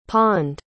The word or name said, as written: pawned